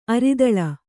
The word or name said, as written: ಅರಿದಲ